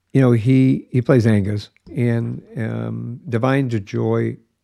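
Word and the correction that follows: Da'Vine Joy: Divine Joy.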